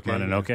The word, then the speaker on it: Mononoke: Mononoke.